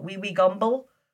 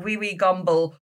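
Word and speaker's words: Wewe Gombel: But it's spelled W-E-W-E, so wee-wee, and then G-O-M-B-E-L, wee-wee gomble.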